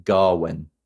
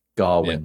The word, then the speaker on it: Gawain: Gawain, and The Green Knight. It's quite interesting because you read it as Gawain, but in the movie, they pronounce it Garwin. – Right, okay, yeah. – Garwin.